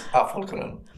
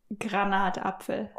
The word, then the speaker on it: Granatapfel: Pomegranate, because in German it's Apfelgranate. – Almost. – Apfelgranate? What is it? – Almost. Granatapfel. – Ah, the other way around.